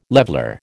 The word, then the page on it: leveller: American English